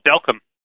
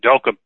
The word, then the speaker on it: Delcambre: there's a town called Delcam in Louisiana. It's actually spelled Delcambre, but they pronounce it Delcam.